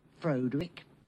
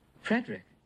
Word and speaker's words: Frederick: Do you also say Frodwick? No, Frederick. Well, why isn't it Frodwick Frankenstein? It isn't. It's Frederick Frankenstein.